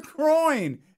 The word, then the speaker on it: groin: No, you're groin. Why are you saying it? There's no G on the end of it.